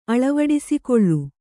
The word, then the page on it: ಅಳವಡಿಸಿಕೊಳ್ಳು: aḷavaḍisikoḷḷu